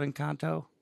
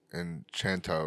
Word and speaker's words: Encanto: Isn't there a fucking Disney movie called Encanto? – Enchanto or something like that. – No, it's Encanto.